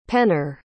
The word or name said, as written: penner